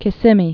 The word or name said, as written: Kissimmee River